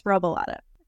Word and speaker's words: Robilotta: It's Robilotta.